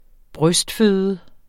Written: brystføde

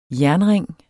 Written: jernring

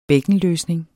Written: bækkenløsning

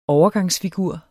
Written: overgangsfigur

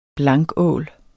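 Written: BLANKÅL

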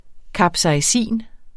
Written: capsaicin

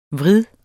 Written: vrid

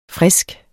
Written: frisk

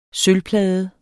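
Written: sølvplader

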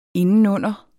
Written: indenunder

